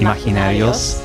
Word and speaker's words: imaginarios: Uh, it's not a hard G on. – Imagi imaginario, yeah. Imaginarios. – Imaginarios.